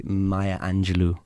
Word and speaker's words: Maya Angelou: I got really into, I Know Why the Caged Bird Sings, by Maya Angelou, who was basically, I hope I'm pronouncing that right. – Yeah, you are.